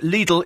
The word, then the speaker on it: Lidl: Bit late, but Lidl is the correct pronunciation of Lidl. I know, because it's German.